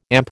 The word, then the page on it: AMP: American English